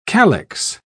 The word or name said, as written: calix